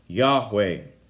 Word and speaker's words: Yahweh: This is the name that he chooses to use, the Hebrew name that is actually it's unclear as to what the correct pronunciation of the name is according to Hebrew scholars, but it's most commonly pronounced Yahweh, Yahweh.